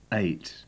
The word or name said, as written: eight